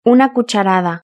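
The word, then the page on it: una cucharada: Spanish (Spain)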